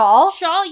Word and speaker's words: Schaal: And she's played by Kristen, is it Schaal? Is how you pronounce it? – Schaal, yeah.